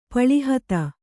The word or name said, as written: ಪಳಿಹತ್ತ